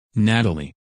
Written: Natalie

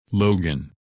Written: Logan

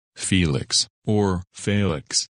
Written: Felix

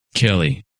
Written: Kelly

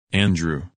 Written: Andrew

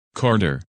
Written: Carter

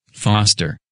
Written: Foster

